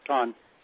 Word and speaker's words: Sean: Then the other question I have is, I haven't heard much from Chan. – Sean rhymes with John. Sean.